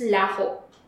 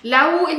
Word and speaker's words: Lawu: The version of the Tagalog in the Kapampangan is very similar, but the name is Laho. Or Lawu. – Lawu. – Lawu in Kapampangan because we don't pronounce our H's.